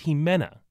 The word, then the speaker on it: Ximena: Then there's Jimena. That's spelled X-I-M-E-N-A.